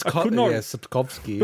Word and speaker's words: Sapkowski: Yeah, it's Sapkowski.